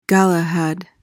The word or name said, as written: galahad